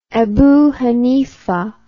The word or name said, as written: Abu Hanifah